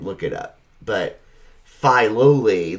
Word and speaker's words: Filoli: Or, I'm sorry, it's pronounced Filoli. I'm sorry, Filoli.